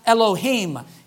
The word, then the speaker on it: Elohim: His name in Hebrew in Genesis 1 is a word that's pronounced Elohim.